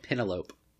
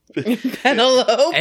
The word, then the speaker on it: Penelope: I call it Penelope. – Penelope.